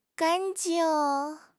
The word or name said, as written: gan1 jing4